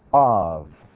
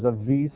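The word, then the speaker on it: av (אב): This letter, bet, can be pronounced as a hard B sound or as a V sound, av.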